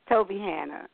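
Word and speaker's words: Tobyhanna: If ever there were a king, he would have said Hana instead of Hannah. Okay, good. – Yes, Hannah. – Okay. – Yeah. Toby Hannah, yes.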